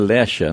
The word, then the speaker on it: Lexa: Que é Lecha, né? Lecha e, Escreve L-E-X-A, né? Então a pronúncia. Eu não, não a conheço, também leria como Lexa, né? E não é. é o correto é Lecha, né?